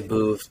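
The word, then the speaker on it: Boeve: I'm not actually sure how to pronounce the latter. – Yeah, it's pretty close. I think it's like Bova. – Bova. – I just say Bove.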